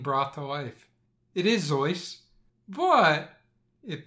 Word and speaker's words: Seuss: So Zoyce is the way the family pronounced the name. It's the way Teddy pronounced the name. But Americans, being Americans, decided it's Seuss, and Seuss it has been and will likely always be.